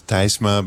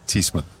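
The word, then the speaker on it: Tysma: It's T-Y-S-M-A. So you would pronounce it in Dutch, you would pronounce Thysma, but in English it's usually Thysma.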